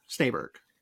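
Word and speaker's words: Snejbjerg: Snaberg. S-N-E-J Berg.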